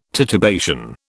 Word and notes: titubation: British English

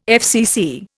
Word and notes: FCC: Indian

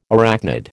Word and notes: arachnid: American English

